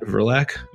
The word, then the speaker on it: Verlaque: You can get me on Twitter at Matt Verlaque, V-E-R-L-A-Q-U-E.